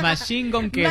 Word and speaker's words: Machine Gun Kelly: Machine Gun Kelly.